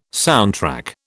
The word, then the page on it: soundtrack: British English